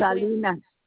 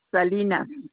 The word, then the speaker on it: Salinas: Norma Salinas. – Last name? Spell it for me, please. – Salinas. Salinas. – S-A-L. – I-N-A-S. – N-A-S, and that first name is Norma. – N-O-R-M-A? – Yes, ma'am.